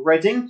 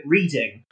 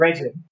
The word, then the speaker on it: Reading: We're in a town called Reading, or as a lot of automated like Siri and that sort of voice things like to call it, Reading, because it is spelled the same.